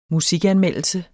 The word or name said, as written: musikanmeldelse